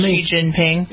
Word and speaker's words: Xi Jinping: The fact that there are demonstrations going on, people are being killed because of their stance, and this fellow Xing Zing Bling, whatever his name is, who's the president. People say that's pretty disrespectful the way you pronounce his name. – You're talking about Xi Jinping.